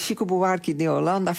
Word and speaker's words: Chico Buarque de Holanda: Ik hoorde je onnavolgbare Portugese uitspraak, maar Chico Buarque de Holanda, hè? – Ja, zo heet hij. Hij heet Chico Buarque de Holanda.